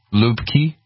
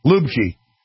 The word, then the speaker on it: Lübcke: Lübcke. – Lübcke.